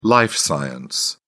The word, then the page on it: life science: British English